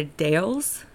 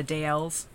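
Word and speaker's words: Dalles: In the Dales? – Yes. It's a city called the Dales. – Oh, okay. – Or the Dales. They pronounce it both ways.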